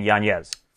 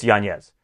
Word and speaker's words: Yanez: And for him to honor his father, I think we should honor his father by saying it properly, saying Yanez. – Fair enough. – For me, at least for here on out, it's Yanez.